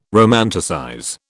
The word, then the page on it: romanticize: British English